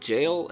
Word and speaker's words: gaol: On his first day at Maitland Jail, I think is how you say that. It's spelled G-A-O-L, jail.